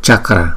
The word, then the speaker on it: Chakra: Om Om Om Vakra Vakra Vakra Chakra Chakra Chakra. Chữ C chúng ta sẽ đọc là ch ch chakra chakra.